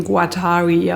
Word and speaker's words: Guattari: Die Amerikaner sprechen ihn Guattari aus. Aber er ist Franzose. – Und Guattari, nochmal, sag's nochmal? – Guattari, glaube ich.